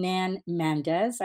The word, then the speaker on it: Nan Mandes: I'm Nan Mandes.